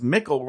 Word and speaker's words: Mikkel: I have a lot to say about this door store, but before we do, Travi Cosmos, with some kind of authority, says his name is pronounced Mikkel, rhymes with tickle.